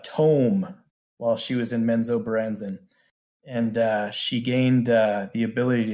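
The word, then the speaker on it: Menzoberranzan: It's hard to pronounce. Baranzan. – Minzo-Branzan.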